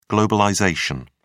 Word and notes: globalisation: British English

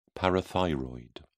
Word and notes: parathyroid: British English

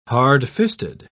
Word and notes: hardfisted: British English

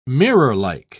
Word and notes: mirrorlike: British English

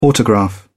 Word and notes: autograph: British English

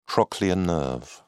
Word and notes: trochlear nerve: British English

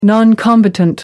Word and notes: non combatant: American English

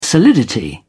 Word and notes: solidity: British English

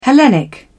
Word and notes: hellenic: British English

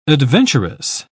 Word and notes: adventuress: British English

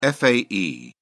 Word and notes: fae: British English

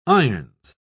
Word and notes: irons: British English

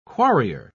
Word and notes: quarrier: British English

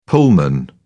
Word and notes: pullman: British English

